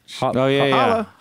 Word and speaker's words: challah: Challah. How you say it? Challah bread? – Challah.